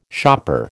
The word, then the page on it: shopper: American English